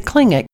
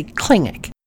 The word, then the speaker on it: Tlingit: She is kind and patient as she tells me to put my tongue in front of my teeth and make an L sound as I blow the air out to say Tlingit.